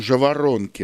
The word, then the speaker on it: жаворонки: Их называют жаворонки, а чаще, по моим наблюдениям, жаворонки. Вот такое ударение.